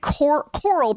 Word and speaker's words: Coral: Is there anything else you'd like to add about maybe, is it Coral Brown, not Carol, correct, is his wife? – Yes.